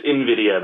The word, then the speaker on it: NVIDIA: NVIDIA's Shield. Did I pronounce that right? – It's NVIDIA, but yeah, it's kind of hard to say.